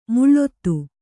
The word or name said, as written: ಮುಳ್ಳೊತ್ತು